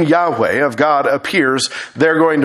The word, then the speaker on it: Yahweh: which we sometimes pronounce as Yahweh, or we'll sometimes say it as Jehovah, which is wrong. There's no J sound in Hebrew.